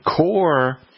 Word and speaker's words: coir: Well, a company called Black Gold, they have a product called Just Core, and it's spelled C-O-I-R. And Core is replacing peat moss.